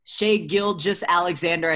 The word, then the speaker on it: Shai Gilgeous-Alexander: So, Shai Gilgeous-Alexander, I said it right that time.